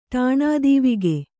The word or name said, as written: ಠಾಣದೀವಿಗೆ